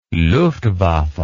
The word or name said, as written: Luftwaffe